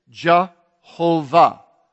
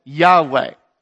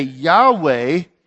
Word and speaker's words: Yahweh: And they put the vowels in Yahweh. So when we say Yahweh, we really mean the same thing as Jehovah. Yahweh, Jehovah, or Lord with the lower caps.